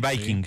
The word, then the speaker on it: Viking: ¿Y el Viking, se dice así? – Sí, el Viking.